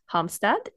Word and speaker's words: Halmstad: So I'm here in the New Zealand head office today, and Tekla's tuning in from Halmstad in Sweden.